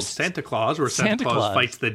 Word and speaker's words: Santa Claus: They're making clear, you spell it S-A-N-T-A-C-L-A-U-S. – Right, but it's pronounced Throat Warbler Mangrove. No, it's pronounced Santa Claus, apparently.